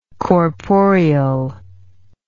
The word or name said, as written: corporeally